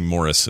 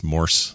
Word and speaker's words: Morse: People always think we say Morris. It's not. It's Morse. Like Morse, I believe is how you spell it. – Morse code. – Like the code.